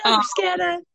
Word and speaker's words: bruschetta: So we tried an eggplant bruschetta is the correct pronunciation, which I refuse to pronounce it right. – Bruschetta.